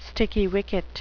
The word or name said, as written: sticky wicket